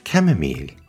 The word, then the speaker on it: Camomille: Camomile is spelt in the US as C-H-A-M-O-M-I-L-E. Or in the UK, it's C-A-M-O-M-I-L-E. But it's pronounced the same. But in French, it's pronounced Camomille and spelled C-A-M-O-M-I-L-L-E.